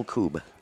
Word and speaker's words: Kubb: A lot of texters apparently want to know how to spell Kub. – Ah, I'm glad they asked. K-U-B-B.